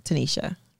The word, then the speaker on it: Tanysha: What's it, Tanisha? – We are reading it. – Spell it. – T-A-N-Y-S-H-A. – Yeah, that's Tanisha.